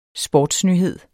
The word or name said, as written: sportsnyhed